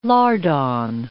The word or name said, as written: lardon